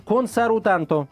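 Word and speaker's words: консарутанто (コンサルタント): А, по-японски консультант консарутанто. Я тебе серьезно говорю, консарутанто. Так произносится. Пишется по-другому, пишется всякими черточками разными.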